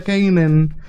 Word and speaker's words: Canaan: Se pronuncia Kanan. Eh, se deletrea C-A-N-A-A-N. ok, Kanan.